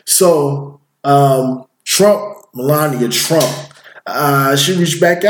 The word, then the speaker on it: Melania: I, I might say that name wrong. Ms. Melania. Melania, Melania. Trump. Right, Melania Trump.